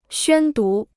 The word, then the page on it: 宣读: xuān dú